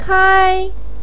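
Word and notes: kai: tone 1

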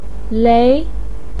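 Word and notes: lei2: pinyin; tone 2

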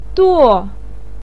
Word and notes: duo: 4

